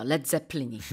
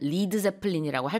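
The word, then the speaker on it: Led Zeppelin: 레드 제플린이. 그런데 사람들이 발음을 리드 제플린이라고 할까 봐 그냥 스펠링을 LED라고 썼답니다.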